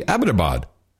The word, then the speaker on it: Abbottabad: I'm like, yeah, I bet you were because you pronounced it correctly. Abbottabad.